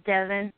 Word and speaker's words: Devon: Hi, my name is Devin. – Hello? Devin? – Yes, ma'am. – Okay, could you spell that? – D-E-V-O-N.